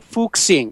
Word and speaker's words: Fuxing: It's called the Fuxing, F-U-X-I-N-G in English.